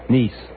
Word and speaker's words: Nice: Nice. The city's called Nice. It's spelled the same way as the word Nice, but they pronounce it Nice over here.